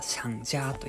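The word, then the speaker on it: 想家: はい、えー、お聴きいただきましたのは、えー、っと、そうか、思う、思う家と書いて、中国語ではシャンジャーというんですけど、ホームシックとか、あの、故郷を思うとか、そういう感じの意味のアジアンテイストのあるインスト曲でした。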